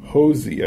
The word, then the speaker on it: Hoese: I want Cody Hosey, I think is how you say his last name, Hoese, H-O-E-S-E, a third baseman from Tulane.